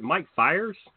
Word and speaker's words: Mike Fiers: Is it Mike Fiers? Is that how you say that? I've never known how to say his name. – Yeah. Yeah, okay.